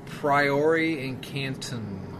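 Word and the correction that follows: Priori Incantatem: Priori incantatum.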